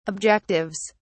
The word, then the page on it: objectives: British English